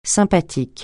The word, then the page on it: sympathique: francais